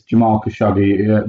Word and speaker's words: Jamal Khashoggi: Yeah, this is Jamal Khashoggi, the journalist who had spoken out against Saudi Arabia, who of course was horrifically murdered in Istanbul.